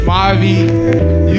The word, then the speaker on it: Mavi: My name is Mavi.